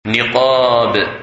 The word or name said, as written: Niqab